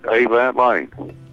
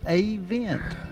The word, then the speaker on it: Avent: It's so easy. It's like air conditioning vent. Avent. Full of air.